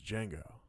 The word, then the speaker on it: Django: It was Django. He's like, "Can you spell it?" And he spells it out for me. He goes, "The D is silent." And he goes, "I know."